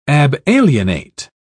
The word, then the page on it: abalienate: British English